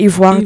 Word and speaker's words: Ivoire: Am I saying that right? – Ivoire Delice. So it's IVOIRE. Delice is written like DELICE.